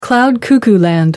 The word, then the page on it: cloud-cuckoo-land: American English